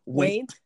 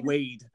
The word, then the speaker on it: Waite: Well, the shot game of today is how often can you mispronounce Arthur Edward Waite? – Waite? – Waite. You're saying Wade. – Wade. Yeah. – Like Wade Barrett or Wade in the water.